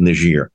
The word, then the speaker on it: Niger: No one even knows what Niger is. Not Niger. Sorry, I'm mispronouncing it. Niger. I must get the proper French pronunciation.